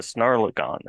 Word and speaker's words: Snarlagon: This is the Snarlagon version, the Snarlagon edition. Did I get that pronunciation? – You did. You nailed it.